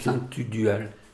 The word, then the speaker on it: Tugdual: Bah, eux, ils disent Saint-Tudal, c'est okay. Saint-Tudual. – Parce que ça s'écrit Tugdual.